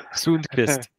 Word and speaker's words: Sundqvist: Sundqvist.